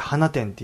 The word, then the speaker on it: 放出: 放出と書いて花店って読んだりするじゃん。